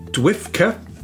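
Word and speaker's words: DWFCA: The usage was more common among fans in the United States during the 1980s, when the Doctor Who Fan Club of America, pronounced by her members as Dwifka, now defunct sadly, published the Whovian Times as its newsletter.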